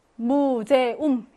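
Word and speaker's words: Museum: 그래서 나머지는 다 오이인데 무제움은 무제움 이렇게 세 음절짜리, 삼음절짜리 단어이므로 에우를 하나로 보면 안 되고 어떻게 해야 돼?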